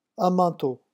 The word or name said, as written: un manteau